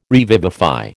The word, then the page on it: revivify: American English